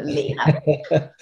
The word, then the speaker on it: Leila: Great, but pronounced Leela, not Leila, Leela. Not Leila, not Lila, Leela.